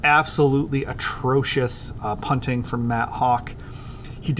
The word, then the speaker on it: Haack: My final takeaway was Hawk, more like Hack. It's a play on words because Matt Hawk's name is spelled H-A-A-C-K.